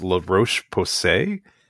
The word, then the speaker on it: La Roche-Posay: And there's a company called, I think it's pronounced La Roche-Posay, LOLARocheposay. – Yeah. – Which is terrible. – No, it's okay. That's how I would pronounce it too.